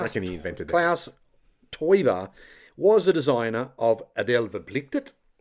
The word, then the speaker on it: Teuber: His second name rhymes with Neuber. – Klaus Teubahn? – Yes. – Close. – And Klaus Teuber.